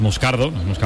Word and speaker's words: Moscardo: Entonces, sí, como, como bien ha pronunciado Unai, es Moscardo, brasileño, sí, sí.